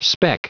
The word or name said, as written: speck